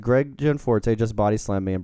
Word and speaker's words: Gianforte: I think having a worse week than Donald Trump is Republican candidate for Congress, Greg Gianforte. Am I pronouncing this right? – Sure.